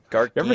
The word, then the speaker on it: Gargano: Gargano.